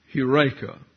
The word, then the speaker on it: Heureka: So there is a city down I-44 called Eureka, and that doesn't observe the long E here, the Eta, and therefore the rough breathing on the EU diphthong. It's actually pronounced Heureka.